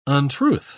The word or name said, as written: untruth